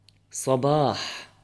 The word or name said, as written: صباح